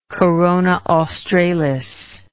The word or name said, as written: Corona Australis